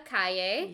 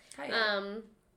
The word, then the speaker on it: Calle: Her name is Sasha Calle. – Yes. – And it's spelled like street in Spanish. – Yeah. – Calle.